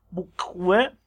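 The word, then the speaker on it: boQwI': بوکوه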